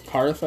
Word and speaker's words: Hearth: And we did get quite some more fire support in Unified Minds in the Giant Earth, or whatever, however you pronounce it. – Hearth, I believe.